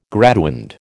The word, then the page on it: graduand: American English